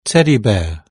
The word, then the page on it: teddy bear: British English